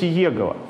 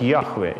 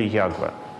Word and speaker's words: Яхве: И получилось «Иегова» – заведомо неправильное произнесение имени Божия, заведомо неправильное. Скорее всего, оно произносилось как «Яхве» или «Ягве». Но вернемся к истории.